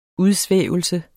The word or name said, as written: udsvævelse